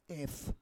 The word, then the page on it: ef: Roon